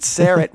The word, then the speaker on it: Syrett: Richard Syrett rhymes with carrot.